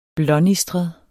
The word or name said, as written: blånistret